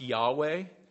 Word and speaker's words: Yahweh: When we see the word Lord in capital letters, that's a translation of a Hebrew word that we pronounce Yahweh.